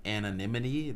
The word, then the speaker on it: anonymity: few years, if there are going to be more artists coming out with anonymity. That's mm-hmm. the one word yeah. that I cannot ever say. – I struggled with that. – Anonymity. – I butchered that. – That's how you say it.